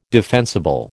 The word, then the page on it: defensible: American English